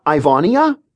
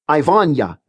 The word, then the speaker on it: Ivania: Is it Ivania? – Not quite. Combine the I-A so the ending sounds like ya. Ivania, not Ivania.